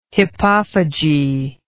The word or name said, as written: hippophagy